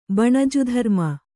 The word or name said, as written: ಬಣಜುಧರ್ಮ